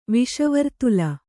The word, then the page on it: ವಿಷವರ್ತುಲ: viṣa vartula